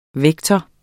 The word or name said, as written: vektor